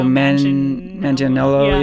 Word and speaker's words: Manganiello: Oh, Manganiello, yeah.